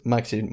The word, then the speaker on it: Maxim: Em, creo, que lo pronuncie como Maxim, pero es Maxim, ¿no? Efectivamente, es Maxim, muy bien.